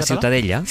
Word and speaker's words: Ciutadella: ¿cómo se pronuncia? – La Ciutadella. – Ciutadella. Ciutadella. Bueno, pues Ciudadela también en castellano.